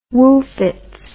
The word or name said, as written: woofits